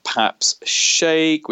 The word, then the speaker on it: Paps Shaikh: Okay, so more about myself, you can just find me on LinkedIn at Paps Shaikh, which is P-A-P-S, and the surname is spelled S-H-A-I-K-H.